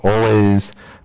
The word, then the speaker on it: Alvvays: They call Always. It's spelled A-L-V-V-A-Y-S.